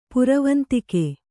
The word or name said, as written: ಪುರವಂತಿಕೆ